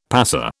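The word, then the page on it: passé: British English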